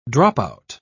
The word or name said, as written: dropout